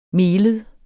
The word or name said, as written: melet